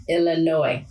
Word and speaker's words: Illinois: The S is silent, Illinois. So it's almost like a French word. Don't pronounce that last letter.